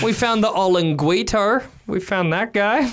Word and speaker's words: olinguito: How do you say olinguito.